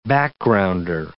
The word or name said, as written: backgrounder